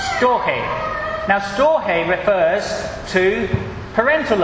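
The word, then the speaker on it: storge: Storch, that's a pretty good go. It's actually Storche, I think.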